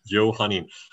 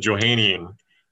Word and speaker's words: Johannine: Johannine. I would have said Johannian, but Damon this Sunday in church taught me the pronunciation of a word that I've read many times but never said out loud.